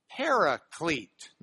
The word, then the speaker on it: paraclete: paraclete, with a long E, paraclete, not a parakeet, a paraclete, okay?